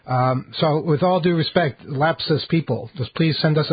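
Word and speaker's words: Lapsus$: It's L-A-P-S-U-S dollar sign. How do you pronounce it? It's Lapsus.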